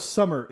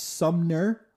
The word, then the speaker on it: Sumner: I thought it was Summer. It's spelled horribly wrong unless the birth certificate had a smudge on it. It's Sumner. Like S-U-M-N-E-R.